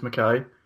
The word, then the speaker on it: McKay: I think I'll be able to do it in the next few years, one by one, tell them that my name's pronounced McKay.